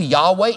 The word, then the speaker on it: Yahweh: It's the YHWH, YHWH, and we don't know the pronunciation exactly. Now, Hebrew scholars tell us we know pretty close, that it's pretty close to Yahweh, if not Yahweh itself, but this is the actual name of God.